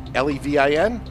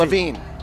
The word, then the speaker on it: Levin: Is it Levine? Levin. That's it.